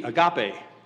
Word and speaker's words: agape: The actual Greek word, whether it's love or charity, in whatever translation you have, is spelled in English, A-G-A-P-E, agape, agape love.